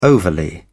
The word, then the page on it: overly: British English